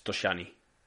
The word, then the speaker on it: Toscani: Toscani, o no sé cómo se pronuncia, Toscani. – Si utilizamos la pronunciación italiana, que no, esto no sé si es un apellido que exista de verdad, pero es Toscani.